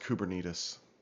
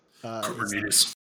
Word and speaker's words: Kubernetes: I think it's actually pronounced Kubernetes. – Kubernetes.